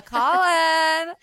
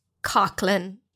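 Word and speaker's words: Coughlan: And we are here with Irish superstar phenomenon, icon, diva, goddess, Nicola Collin. – Hi. – Now, hey, you know what? Your last name is Irish pronunciation, which not everyone would know. – Oh, Collin. – So hilariously, it's actually pronounced Cochlin.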